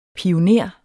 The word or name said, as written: pioner